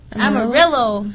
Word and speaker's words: Amarillo: Amarillo.